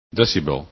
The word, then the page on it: decibel: english